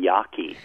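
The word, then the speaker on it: Jaeggi: I made in researching the book was how to pronounce this lady's name. It's actually Susan Yaki.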